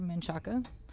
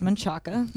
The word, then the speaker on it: Manchaca: We're in South Austin off of Manchac Road, 11500 Manchaca. – In Austin, you say Manchac. – We say Manchac in Austin because that's how they all pronounce it. In real life, it's Manchaca. But it's about 15 minutes south of downtown.